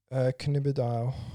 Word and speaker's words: Cannabidiol: Cannabidiol.